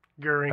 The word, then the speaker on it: Goering: Goering. – Hermann Goering.